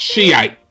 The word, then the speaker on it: Shiite: It's pronounced Shiite.